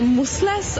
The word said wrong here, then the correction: muscles: or like massless. Musless? Massless? – What is this? – Like this. – Oh, muscles. – Yes, muscles. Musless.